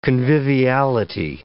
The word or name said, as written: conviviality